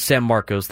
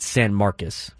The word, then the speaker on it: San Marcos: It's spelled San Marcos. They call it San Marcos.